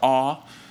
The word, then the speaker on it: Oux: For this patio show, they're going to have the band Aux, O-U-X. You can pronounce it Aux or Aw.